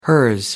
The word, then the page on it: hers: American English